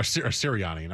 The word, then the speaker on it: Sirianni: Um, what's interesting about this is that the Eagles hired their new head coach, the Colts guy, v- Verini, I think his name is, or Variani. I forget how you say his name. Because, uh, or, or, uh, Sirianni, not Variani. Uh, Nick Sirianni is his name.